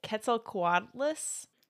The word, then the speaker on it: Quetzalcoatlus: Quetzalcoatlus? – That's exactly how you say it.